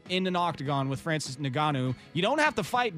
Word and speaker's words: Ngannou: Uh, Ngannou, yeah, I believe. Ngannou, yeah.